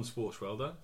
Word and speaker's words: Swartzwelder: That's true, but the ones I always think at is John Swartzwelder.